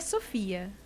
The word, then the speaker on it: Sophia: A gente está falando do olhar do Messi em relação àquele vídeo com a Sophie. Ou Sophie. E agora? Como que será que a pronúncia é certa? – Pelo que eu vi, é Sophia.